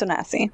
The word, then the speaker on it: Onasi: Today, we are going to be analyzing Carth Onasi? Is that how you say it? Onasi? I don't know how to say this. Onasi? – Yeah, it's Onasi.